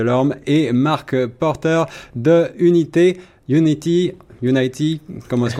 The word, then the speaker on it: UNT: C'est ça, donc euh, pour, euh, pour les auditeurs, ça s'écrit UNT, hein, et on dit unité.